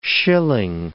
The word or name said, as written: schilling